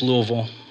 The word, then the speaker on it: Louisville: Even when we went to Louisville a couple weeks ago, and yes, it's Louisville. That's how they pronounce it out there.